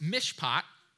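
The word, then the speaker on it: mishpat (משפט): I will say this, the first one there under Hebrew is pronounced mishpat.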